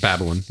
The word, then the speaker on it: Babylon: Babylon.